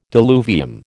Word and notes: diluvium: American English

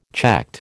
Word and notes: checked: American English

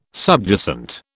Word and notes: subjacent: British English

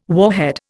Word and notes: warhead: Indian

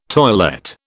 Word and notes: toilette: British English

